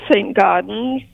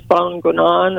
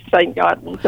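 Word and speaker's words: Saint-Gaudens: I pronounce it St. Gaudens. My husband has a better handle on French, so he says Bon Gonon, or I don't do it justice. Most of us say St. Gaudens, unless you're in France, then they know how to say it.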